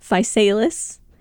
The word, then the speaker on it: Physalis: Looked up the pronunciation of that several times. Yes, that is how they say to say it, Physalis.